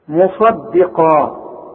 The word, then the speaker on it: مصدقا: مصدقا خد بالك في عندك صاد عاملة زي الجبل وقاف عاملة زي الجبل وبينهم دار رؤيئة فالدال دي هتطلع منك ضاد إذا ما خدتش بالك من نطقها لازم تقول لي